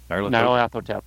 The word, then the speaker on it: Nyarlathotep: And this is handcrafted props for the London and Cairo chapters of the Call of Cthulhu campaign by Chaosium, the Mask of Nyarlathotep. I can't even pronounce it. – Nyarlathotep. – Okay, thank you.